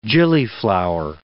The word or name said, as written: gillyflower